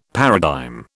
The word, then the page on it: paradigm: British English